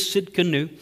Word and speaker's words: Tsidkenu: We kind of joked on it, Jehovah Tsidkenu, and that's kind of how we started to pronounce it. But it is Tsidkenu. It's not a term that we use in today's vernacular.